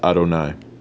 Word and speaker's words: Adonai: Hashem, or when we read Torah and pray, it is pronounced Adonai.